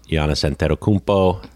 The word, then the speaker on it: Giannis Antetokounmpo: I loved Giannis Antetokounmpo.